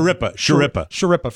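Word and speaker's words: Schirripa: So here's Michael Imperioli, who played Christopher, and Bobby Shripa, I guess is how you say his name, talking about Shripa.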